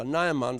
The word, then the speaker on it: Naaman: Nur der Syrer Naaman oder Naaman, so wie ja, es wird unterschiedlich ausgesprochen.